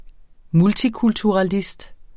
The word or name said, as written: multikulturalist